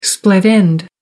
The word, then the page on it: split end: American English